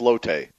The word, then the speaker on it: Lotte: Somebody says Lotte Giants fan since day one. See, there you go. There's others out there. – I think they're the Lot Giants, aren't they? – Well, I think it's pronounced Lotte.